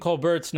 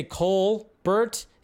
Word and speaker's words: Colbert: Okay, so he's gotten to the point where he says I'm even saying Stephen Colbert's number or name wrong. It's because I say Colbert, and he goes, it's Colbert.